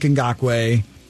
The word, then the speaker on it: Ngakoue: They're going to trade Yannick Ngakoue. That's how it's pronounced.